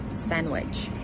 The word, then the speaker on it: sandwich: Пишемо sandwich, читаємо sandwich. Пишемо handsome, читаємо handsome.